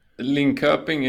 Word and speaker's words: Linköping: Linköping is a good way to pronounce it. If you want to do the proper accent locally, it's Linköping.